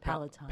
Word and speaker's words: Palatine: and worked on the Palestine excavation. Did I pronounce that? – Palatine. – Palatine. Oh, see, I thought there was just an S missing.